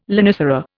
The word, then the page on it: lonicera: Indian